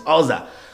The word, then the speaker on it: Uzzah: The same thing happened to, Uz, to Uzzah Oza, or, or some people call it Uza, but I, I, I went to dig up the, the pronunciation is Oza.